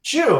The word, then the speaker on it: Xu: Shoe, like wearing a shoe.